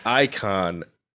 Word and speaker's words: Aikon: It's the Icon. I guess that's how you say yes. – It. – A-I-K-O-N. Yeah, Icon spelled with an A.